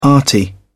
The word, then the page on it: arty: British English